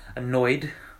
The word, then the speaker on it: Anoyd: So the guy is called Annoyed, right? So that's spelled Anoyd, right? Annoyed, right? If you haven't checked out his Five Fingers of Death freestyle on Sway in the Morning, make sure you check that out.